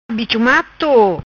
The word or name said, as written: Bicho Mato